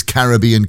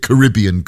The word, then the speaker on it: Caribbean: In the middle from the Sugar Babes, and there's Billy Ocean with his Caribbean Queen. Or is it Caribbean Queen? Doesn't really matter how you pronounce it, because if you win this afternoon £750,000, you can go there.